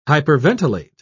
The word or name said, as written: hyperventilate